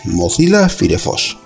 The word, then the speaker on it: Mozilla Firefox: Lo voy a deletrear: MOZILLA-FIREFOX, Mozilla Firefox, que está mal dicho, que realmente el nombre bien pronunciado sería Mozilla Firefox.